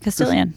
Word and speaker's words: Castilian: Castilian.